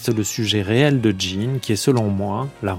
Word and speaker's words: Gen: Benoît Peters, dites-moi si ça peut se dire à quoi ressemble cette américaine prénommée Jean, ce qui s'écrit Jean comme en français, G-E-N, et se prononce Jean.